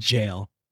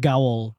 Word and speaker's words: gaol: The spooky site is called the Carleton County, spelled G A O L, but I looked it yeah. up. It's pronounced jail. – Yeah, we don't know how to spell up here. – My dumbass said "gowl."